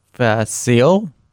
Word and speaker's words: Vasil: Vasil? Something. – Spelled like basil, but with a V.